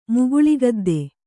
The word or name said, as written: ಮುಗುಳಿಗದ್ದೆ